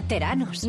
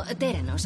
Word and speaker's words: Theranos: Pensaba que era Theranos. – No, Theranos. Es una mezcla entre terapia y diagnóstico. – ¿Por qué no Theranóstico? – Pues porque se llama Theranos.